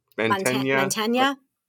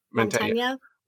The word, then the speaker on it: Mantegna: Mantegna. – Mantegna he's got a like a old timey suit on, right? – He does, yeah.